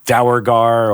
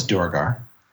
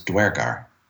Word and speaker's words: Duergar: It has two canonical pronunciations. Unu- what, unusual. What is that? One is Duergar. The other is Dwergar.